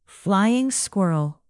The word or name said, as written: flying squirrel